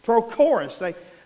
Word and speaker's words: Prochorus: Then Prochorus. I'm going to say it in two syllables. Prochorus.